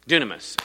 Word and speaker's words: Dunamis: And you know, the other one is Dinah. How do you say that? Dunamis.